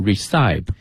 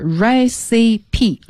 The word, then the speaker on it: recipe: 有的同学看到这个单词，如果没听到过它的话，可能会读成 recipe。 – 对，但是这个词的发音呢，正确的是 recipe。 – 哎，它是三个短促的音节、 recipe 啊。